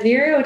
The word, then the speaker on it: Vireo: And if you want to find out more about Vireo and her programs, you can check out pleasurecoachvireo.ca, and Vireo is spelled V-I-R-E-O. So as Vireo told me how to pronounce her name, it's like Vireo, like Cheerio.